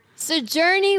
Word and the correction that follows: Sigourney: Weaver. I'm probably mispronouncing her first name. Sigourney. – Sigourney Weaver. Okay.